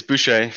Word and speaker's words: Boucher: It's Boucher, French Canadian.